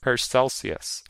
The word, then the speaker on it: Paracelsus: This week's episode of Warehouse 13 allowed us to get to know Season 4's Big Bad, whose name we discovered is pronounced Paracelsus.